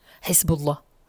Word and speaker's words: Hezbollah: Eh, sí, viene de la palabra Hezbollah, que es partido, partido político, y Allah, que es partido de Dios. Entonces sí, viene de ahí, Hezbollah sería la pronunciación correcta.